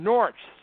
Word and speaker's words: Norwich: And I said it wrong. Norwich. Sorry, David Hulley.